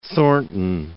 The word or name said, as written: Thornton